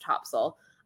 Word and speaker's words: Topsail: And one day we realized after we'd been staying there for years that we'd been pronouncing it wrong all along. So I now have been corrected by true North Carolinians and I know that it is pronounced Topsail.